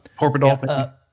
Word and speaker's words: Horpedahl: And then typically guys like Gene Epstein and uh, Jeremy is it Harpadal? I hope I'm saying his name right. I'm not um, sure. – Horpedahl.